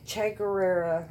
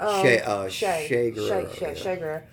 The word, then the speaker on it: Che Guevara: Well, it's like you had, you know, Che Guevara. I think I said his name wrong. – Che Guevara.